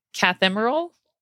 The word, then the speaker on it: cathemeral: cathemeral, which I'm pronouncing that correct because I wrote it out phonetically.